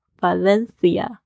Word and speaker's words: Valencia: 啊，我第一站呢就是去了 Valencia 瓦伦西亚，二点五个小时距离巴塞罗那，所以这一天呢我就索性跳上火车，第一站呢就到了 Valencia。你在讲这个西班牙语的时候呢，c 要变成的声音，就是有点像我们 th 的这个发音，所以会咬舌头。 Valencia。